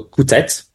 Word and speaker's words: Coutet: it's been in the same family for Chateau Coutet. So you pronounce the T, so C-O-U-T-E-T.